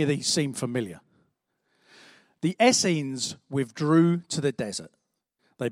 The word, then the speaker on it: Essenes: We had the Essenes. Yes, I did have to Google how to pronounce that. It told me it rhymes with beans.